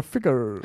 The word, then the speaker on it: Figure: Figure, also nicht Ficker, sondern Figure.